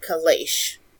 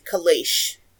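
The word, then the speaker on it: Cealleach: I believe it's pronounced Kalash. – Kalash? – If I mispronounce it. Yes, Kalash. It's C-E-A-L-L-E-A-C-H.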